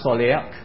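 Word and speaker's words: soleak (צלח): I won't spell it all for you and all that, but it's pronounced soleak.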